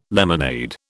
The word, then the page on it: lemonade: British English